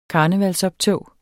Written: karnevalsoptog

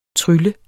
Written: trylle

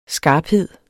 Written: skarphed

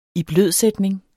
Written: iblødsætning

